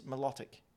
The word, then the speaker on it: Milotic: But if you listen to the actual pronunciation in the official Pokédex announcer in both the anime and on the 3DS, the Pokédex thing you have on there where I pronounce the names, mm-hmm. – it's Milotic.